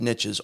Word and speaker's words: niches: So it has to be pronounced niches only for this simple reason, because it rhymes with riches. And the phrase is that the riches is in niches, right?